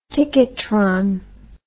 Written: ticketron

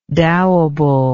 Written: dowable

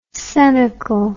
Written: coenacle

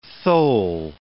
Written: thole

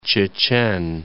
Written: Chechen Republic

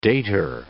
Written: dater

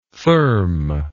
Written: thurm

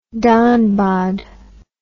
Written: dhanbad

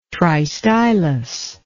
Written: tristylous

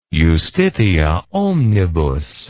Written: justitiaomnibus

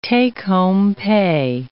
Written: take-home pay

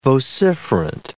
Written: vociferant

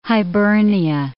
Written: Hibernia

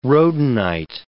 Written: rhodonite

